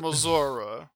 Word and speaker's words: Missouri: or the way that the governor of uh is it Missouri? Missouri, I believe. I believe it's pronounced Mis- Missouri.